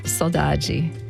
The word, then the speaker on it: saudade: And I have the Brazilian pronunciation, saudade.